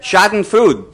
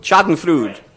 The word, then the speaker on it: Schadenfreude: There's a German word, I think it's pronounced Schadenfreude. Schadenfreude. Schadenfreude.